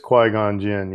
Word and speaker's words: Qui-Gon Jinn: It is Qui-Gon Jinn.